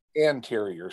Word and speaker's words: anterior: Anterior side. Oh, it's A N T E R. Anterior? – You yeah. said arterior. – Oh, I did. Anterior. Imagine that. I, I mispronounced something.